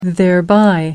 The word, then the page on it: thereby: American English